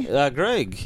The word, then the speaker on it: Greg: Greg. Yeah. – Greg. – Greg, okay. – Yeah, G-R-E-G. – G-R-E-G.